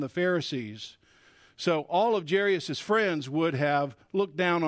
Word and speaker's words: Jairus: Jairus is really how you say his name.